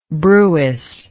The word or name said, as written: brewis